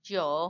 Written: yoo

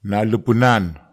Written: nalepenan

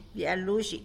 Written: bya luzhy

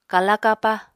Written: kala-kapa

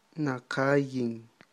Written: naqayin

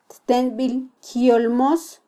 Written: ttenbilkyolmos

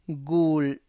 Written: gul

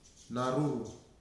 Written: Naruru

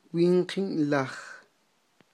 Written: winqi'n laj

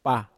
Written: pa

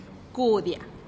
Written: kudia

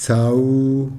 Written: tsau

